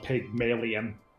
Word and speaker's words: Pygmalion: but it's spelled Pygmalion, like alien.